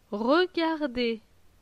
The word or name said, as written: Regarder